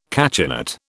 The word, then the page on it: cachinnate: British English